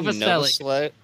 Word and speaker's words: Novoselic: Novoselic. – Novoselic.